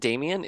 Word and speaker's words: Damian: Is it Damian? Is that how you pronounce his name? – Yeah.